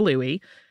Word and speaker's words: Louis: You might see it spelled like Louis with an S or Louis with an E, but it is the same dish with either spelling, and it is pretty much always pronounced Crab Louie.